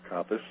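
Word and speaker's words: Coppes: And that's C-O-P-P-E-S, last name. And it is pronounced Kupas.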